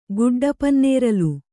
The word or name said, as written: ಗುಡ್ಡಪನ್ನೇರಲು